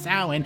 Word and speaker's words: Samhain: I believe it's pronounced Samhain.